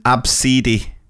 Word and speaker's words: Abcde: I've also taught an absidi. Absidi. Spell A-B-S-I. No, no, it's not spelled that. That's how you pronounce it. Absidi. Spell A-B-C-D-E.